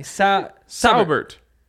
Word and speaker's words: Saubert: You're saying sour like literally. – No, no, there's sour. No R. There's no R. There's never been an R. Just Sauerbert. – No, Saubert. – It's just two syllables. Sau.